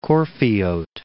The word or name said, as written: Corfiote